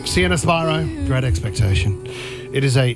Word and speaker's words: Spyro: It's Spyro. Rhymes with Byro.